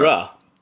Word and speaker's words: Uhura: And Uhura, sorry, how do you say it? Uhura, is it? – Yeah. Uhura. Uhura. Oh, isn't it Uhura? Uhura.